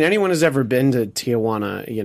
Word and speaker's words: Tijuana: Did you see that that was the reaction in Tijuana? – Yeah, I see it.